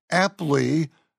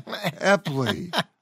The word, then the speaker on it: Epley: I just found it a bit odd that you pronounced it like it was the Apley rather than Epley. – Well, I've seen it. It is with an E. I've seen it written. I really don't know what the correct pronunciation is, so I'll take that as a correction, that it should be Epley's maneuver, not Epley's maneuver.